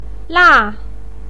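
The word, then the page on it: la4: pinyin; tone 4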